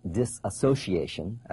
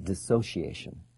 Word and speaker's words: dissociation: Now, that's not disassociation, as it's often mispronounced, but dissociation.